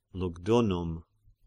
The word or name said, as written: Lugdunum